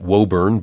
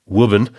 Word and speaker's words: Woburn: I was pronouncing it Woburn, but I guess in New England parlance, it's Woburn, just north of Boston.